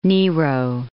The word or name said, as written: Nero